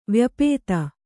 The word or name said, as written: ವ್ಯಾಪ್ತ